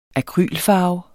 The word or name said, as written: akrylfarve